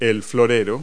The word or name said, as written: vase